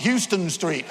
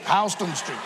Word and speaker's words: Houston: I was told, make sure you know where Houston Street is, not Houston Street, Houston Street.